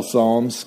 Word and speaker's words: Psalms: which we call Psalms because the P is silent.